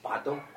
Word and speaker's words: pato: Pato. – Bato. – P-A-T-O, pato.